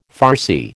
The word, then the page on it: Farsi: American English